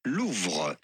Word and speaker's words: Louvre: Louvre.